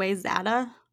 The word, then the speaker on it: Wayzata: Is it like mm-hmm. Wayzata? – Yes, that's what everyone guessed. Um, no, it's actually Wayzata.